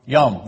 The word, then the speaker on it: Yom: And now that you've learned what it means, how would you pronounce it? Yom, very good.